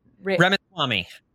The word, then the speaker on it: Ramaswamy: Ramaswamy.